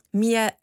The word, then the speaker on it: Miele: Miele, hoe spreek je het uit? Honing? – Miele. – Miele. Nee, want ik dacht al Miele, ik denk wel. – Ja, Miele, zoals de wasmachine.